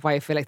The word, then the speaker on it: biophilic: The biophilic, am I saying that right? Design? – Yeah, biophilic.